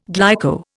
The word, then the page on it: glyco: Indian